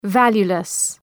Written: valueless

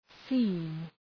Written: seen

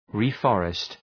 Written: reforest

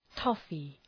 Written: toffee

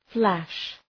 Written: flash

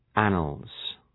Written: annals

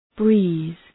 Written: breeze

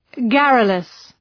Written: garrulous

